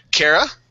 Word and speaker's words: Kara: So, Kara, that's how you say that.